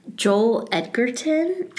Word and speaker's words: Joel Edgerton: Joel Edgerton.